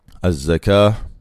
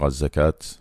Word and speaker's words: zakah: Az-zakah, il y a une tamarbouta à la fin, qu'on prononce comme une ha lorsqu'on s'arrête. Mais en français, les gens ont l'habitude de dire az-zakat, la zakat, en prononçant le T.